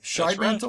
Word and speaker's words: Shidemantle: I have ruling elder Scott Shidemantle on with. Did I say your name right? Shidemantle? – Right.